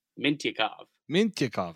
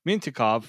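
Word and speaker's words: Mintyukov: We got Pavel Mutoykov. – Mintyakov. – I don't even know why I didn't even say it like that. Mintyakov from Saginaw.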